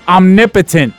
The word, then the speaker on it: omnipotent: First of all, he's not even saying it right. It's omnipotent. Okay? you're omnipotent. No doubt. Yeah. Omnipotent. It's like what God is.